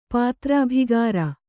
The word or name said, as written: ಪಾತ್ರಾಭಿಗಾರ